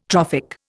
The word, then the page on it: trophic: Indian